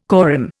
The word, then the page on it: corymb: Indian